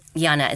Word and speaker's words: Jana: My second name, Jana, is actually soft J, by the way, Bill.